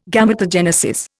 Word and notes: Gametogenesis: Indian

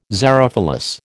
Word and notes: xerophilous: American English

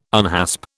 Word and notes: unhasp: British English

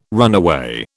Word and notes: runaway: British English

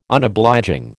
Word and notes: Unobliging: American English

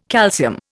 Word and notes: calcium: Indian

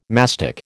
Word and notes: mastic: American English

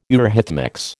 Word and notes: eurhythmics: American English

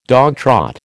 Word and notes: dogtrot: American English

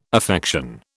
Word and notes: affection: British English

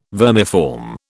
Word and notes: vermiform: British English

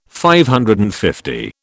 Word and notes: DL: British English

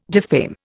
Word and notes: defame: Indian

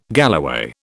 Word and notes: galloway: British English

